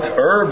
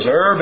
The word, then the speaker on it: herb: I know it's popular now to start pronouncing the H. But when I was brought out, herb was herb and not herb.